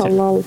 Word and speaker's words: Талал: Александр Талал, не знаю, как правильно поставить ударение, простите. – Талал, да, да. Видимо, Талал.